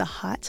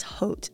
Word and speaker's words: Haute: Yes, it's the Hot Harvest High Fashion Show. – Hot Harvest High Fashion Show. – Oh. You all know it as Haute, Terre Haute, but it's hot. – I was going to say, that's spelled H-A-U-T-E.